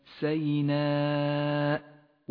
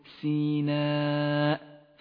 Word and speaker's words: سيناء: والآن اقرأ كلمة سيناء واحذر من اللحن في نطقها، إياك أن تقول سيناء فالسين مفتوحة وليست مكسورة.